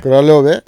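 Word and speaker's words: Králové: Kralove.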